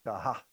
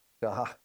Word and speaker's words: shachah: But the Hebrew word for worship, Shahach. It's spelled S-H-A-C-H-A-H. Shahach. At the end. Shahach.